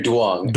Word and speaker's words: Duong: It's Henry Duong.